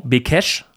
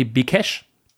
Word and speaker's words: Békés: Sollen wir nicht Bekes sagen, ne, statt Bekesch? Nee, Bekesch. Bekesch. Ja, du, du weißt doch, was solche Accents bedeuten.